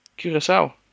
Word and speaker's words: Curaçao: Curacao? Is that how you say it? – Curacao. – Curacao.